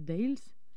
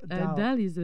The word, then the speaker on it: The Dalles: Before that, we played on Friday at the Dales. – Uh-huh, yep. Dalles.